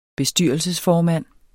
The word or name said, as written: bestyrelsesformand